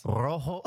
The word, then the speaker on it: rojo: You don't even roll your R's. – Rojo.